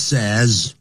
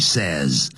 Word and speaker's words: says: He always says, 哎不要念成 says, 这个 say 啊是 I say, you say, they say, everybody says.